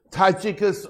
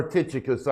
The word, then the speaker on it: Tychicus: Beginning in the next verse, Tychicus or Tychicus, I've heard it pronounced both ways.